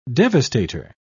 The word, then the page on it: devastator: British English